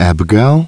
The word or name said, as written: Abigail